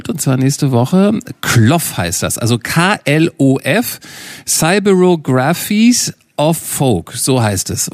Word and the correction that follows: Cyberographies: Oder habe ich es falsch ausgesprochen, Irina? – Cyberographies. – Ich habe es falsch. Ich wusste, dass ich es falsch betone.